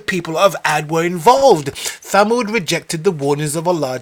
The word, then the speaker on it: Thamud: We're going to look at the people of Thamud right now, or Samud as people pronounce it. The Prophet alayhi salatu wasalam was sent, the Prophet Saleh alayhi salam was sent to people of Thamud.